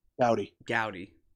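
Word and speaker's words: Goudey: Gowdy. – It's like Fody.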